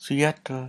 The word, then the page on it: suyatel: Lwaitel